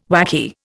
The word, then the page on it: wacky: Indian